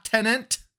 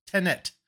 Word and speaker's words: Tenet: Not Tenet. There's not two T's, just Tenet. I hear so many people say Tenet.